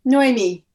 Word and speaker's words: Noémi: Is it Noémie? – Yeah, Noémie. It's kind of got almost two stresses.